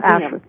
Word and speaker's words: Auschwitz: Once you start digging and different things you can find, I have a book here from the, I'm going to say it wrong again, Auschwitz Museum. – Auschwitz, yes.